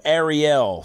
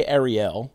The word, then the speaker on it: Arielle: I always want to say it wrong. – You say it right. – I say Arielle. – That's right. – Which is right. But sometimes I'll throw out Ariel every now and then. – That's a little mermaid.